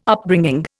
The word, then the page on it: Upbringing: Indian